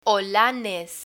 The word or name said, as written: olanes